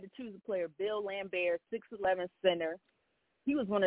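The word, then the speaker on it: Laimbeer: And then my number one overall dirty player to me of all time in the NBA was Bill Lambert. Did I say that right? – Yep. – Yes, you did.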